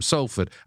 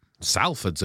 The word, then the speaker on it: Salford: Salford's a great swing at the old pronunciation there. Have you always said that? – I say Salford, yeah.